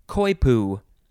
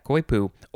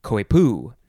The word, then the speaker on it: coypu: Okay, the first one in this episode is koipu. Yeah, koipu. Or you can pronounce it koipu. Emphasize either syllable, C-O-Y-P-U. Noun from 1793. And the synonym is just nutria, N-U-T-R-I-A.